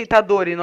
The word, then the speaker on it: Itadori: O I que eles puxam no anime é o primeiro, não é o último. E eu falo Itadori, normal.